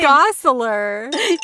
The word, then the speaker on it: Gosselaar: Gosselaar.